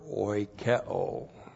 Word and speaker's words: oikeo: H-A-M-A-R-T-I-A. Hamartia in the singular is the sin nature. And then we have the verb oikeo. O-I-K-E long O. Oikeo.